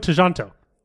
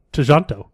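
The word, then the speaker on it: Tjahjanto: It's just Timo Tejanto. Not Timo Tejanto.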